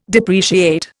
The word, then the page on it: depreciate: Indian